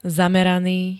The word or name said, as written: zameraný